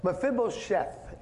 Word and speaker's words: Mephibosheth: Mephibosheth is the right proper way to say it.